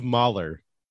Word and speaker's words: Mahler: It's pronounced Gustav Mahler. – Gustav Mahler. – Yeah, I thought it was Molly. Honestly, I thought it was Molly. – I think it is Molly.